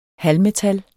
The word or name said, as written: Halvmetal